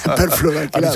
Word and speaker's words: perfluoroalquilados: Percloroalquilados. – Es que no vale decirlo despacio. – PFAS. Es que lo he dicho mal, además, porque es perfluoro. Perfluoroalquilados.